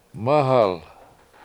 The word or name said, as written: mAhal